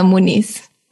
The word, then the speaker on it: Muniz: Yeah, you didn't pronounce the properly Brazilian way, but it's correct. – Okay, what's the Brazilian way? – Juliana Muniz.